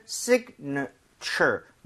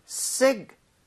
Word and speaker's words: signature: 그래서 앞쪽 I의 강세를 좀 주면서 사실은 signature, 느가 살짝 나오지만 실제로는 발음을 빠르게 들으면 거의 signature. 거의 A 발음이 거의 안 나오게 돼요. – 예, 그러니까 signature.